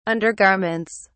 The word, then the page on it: undergarments: British English